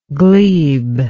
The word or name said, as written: glebeless